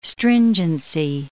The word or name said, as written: stringency